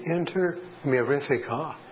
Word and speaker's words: Inter Mirifica: However, the cost of the office, facilities, and so on, if you make your checks payable to Inter Mirifica, that's spelled I-N-T-E-R, next word, Mirifica, M-I-R-I-F-I-C-A, Inter Mirifica.